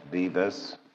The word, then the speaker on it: Dives: When you see it spelled out, it looks like dives. It's pronounced Debus.